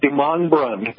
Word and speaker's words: DeMondrum: That's correct. DeMondrum. The emphasis is on the second syllable.